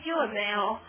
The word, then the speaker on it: juvenile: crime 的感觉的，咱们那少年犯用哪个单词啊？juvenile 哈，看这单词，所以这单词叫青少年的，它发音 juvenile 哈，酒文哈，奶味儿，对不对？